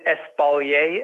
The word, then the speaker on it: espalier: Is it espalier, espalier? – I've heard it both ways, espalier or espalier. It's a French word, so maybe we should ask our French friends up in Quebec or or Quebec, depending on how you...